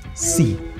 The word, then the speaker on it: see: See. S-E-E. Verbo. See. Muy sencillo, como sí, sí, sí. See. Se pronuncia igual que mar. See. Escrito sea.